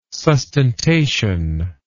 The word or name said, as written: sustentation